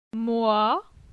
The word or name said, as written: Moi